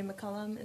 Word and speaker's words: McCollum: McCollum.